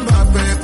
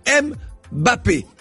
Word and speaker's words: Mbappé: En parlant de positif, il est intéressant qu'en France, les journalistes sportifs ne prononcent plus Mbappé, mais Mbappé.